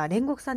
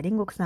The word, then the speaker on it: 煉獄: で、私、煉獄さんっていうのは、ね、読めなかった。煉獄さんっていうんですね、あれは。読み方としては煉獄さん。